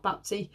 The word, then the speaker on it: Baptie: So his last name is spelled B-A-P, so like a bap, and then T-I-E, like a tie, like a necktie. So Scott Baptie.